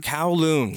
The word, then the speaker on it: Kowloon: Kowloon. – Kowloon.